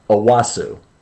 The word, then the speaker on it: Onwuasor: Well, when I say it to Maureen, because I want her to spell it correctly when we're studying our score sheets, I say Onwuasor, so she spells it out correctly, phonetically here, but it's Owosso.